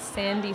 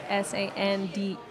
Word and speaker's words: Sandee: Instagram handle is Sandy Soul Sister, so Sandee, so two E's, not Y.